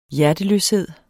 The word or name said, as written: hjerteløshed